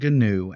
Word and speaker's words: GNU: Because one of the things in the GPL, which is the GNU public license, and yes, I pronounce it GNU, Adam, stop frowning at me. Um, it is GNU, the GNU public license.